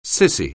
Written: cissy

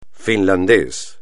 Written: finlandés

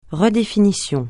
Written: redéfinition

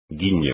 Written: guiño

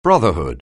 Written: brotherhood

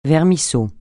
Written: vermisseau

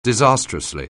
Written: disastrously

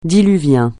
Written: diluvien

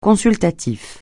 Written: consultatif

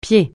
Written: pied

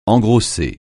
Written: engrosser

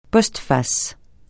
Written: postface

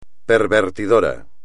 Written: pervertidor

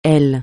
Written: elle

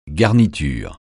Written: garniture

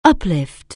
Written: uplift

